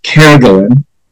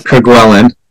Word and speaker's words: Kerguelen: A Frenchman told me it's pronounced Kerguelen, not Kerguelen.